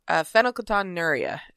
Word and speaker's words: phenylketonuria: And for those who don't know, which is probably almost every single one of you, PKU stands for phenylketonuria is how it's pronounced.